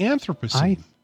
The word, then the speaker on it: Anthropocene: Anthropocene.